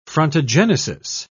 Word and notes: frontogenesis: British English

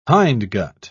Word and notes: hindgut: British English

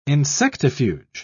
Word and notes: insectifuge: British English

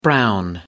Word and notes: brown: American English

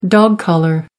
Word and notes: dog collar: American English

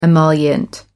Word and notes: emollient: American English

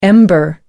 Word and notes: ember: American English

